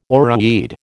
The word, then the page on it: oreweed: American English